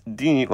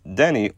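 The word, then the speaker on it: Deni: Though you may see it or hear it as Dini, like Houdini, it's actually pronounced Denny, like that fast food chain that serves way too salty food.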